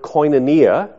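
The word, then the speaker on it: koinonia (κοινωνία): It's the word koinonia, is how you pronounce it.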